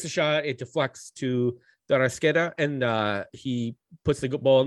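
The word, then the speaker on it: De Arrascaeta: 5 minutes later, someone help me with the pronunciation. De Arasqueta? – It's De Arasqueta. Yep.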